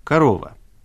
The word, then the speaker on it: Корова: Альбом для нас с вами, носителей русского языка, или, скажем, просто говорящих по-русски, представляет интерес еще и потому, что выпущен он фирмой «Корова», или, если поменять ударение, «Корова».